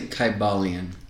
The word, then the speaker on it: Kybalion: From what I was reading with the Hermetic Code, which is Kabbalan, is that, am I saying that right? – I'm going to say Kybalion. – Kybalion, mm-hmm.